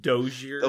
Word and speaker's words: Dossier: So the first one is called Dossier. And Dossier. – It's pronounced Dozier.